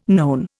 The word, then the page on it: known: Indian